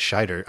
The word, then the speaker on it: Scheider: I keep saying Schneider, and I know it's Scheider, and I always do that.